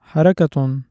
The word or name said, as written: حَرَكَةٌ